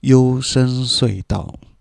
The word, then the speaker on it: 幽声隧道: 啊，前面那个好像发音不太标准，“ 优声隧道”，嗯。这样说比较标准。